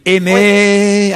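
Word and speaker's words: Eymet: Carole est à Aimé ou Aimette, je ne sais pas comment on doit prononcer. Aimé. C'est en Dordogne. Aimé. Oui.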